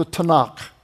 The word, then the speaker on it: Tanakh: It's really TNK or TNCH. Many Hebrew people spell it CH, and they put in the vowel points and they call it the Tanakh.